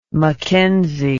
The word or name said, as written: mckenzie